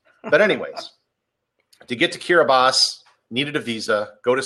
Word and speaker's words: Kiribati: Same way with the way Kiribati is pronounced. It's Kiribati, even though there's no S to be found. The T-I is pronounced like an S.